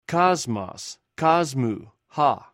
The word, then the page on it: kosmos: Greek